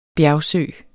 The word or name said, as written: bjergsø